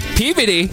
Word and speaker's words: Peabody: No, that's Peabody. I'm sorry. – Peabody? That's how you say it? Peabody.